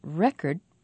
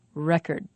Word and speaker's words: record: The first word, the noun record, has the stress on the first syllable. The second syllable is not stressed, and its vowel is not pronounced clearly. Record.